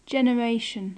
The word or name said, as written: generation